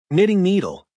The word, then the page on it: knitting needle: American English